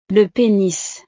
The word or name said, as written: le penis